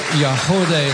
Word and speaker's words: Yagody: The group is called Yahodeh. It's spelled Y-A-G-O-D-Y.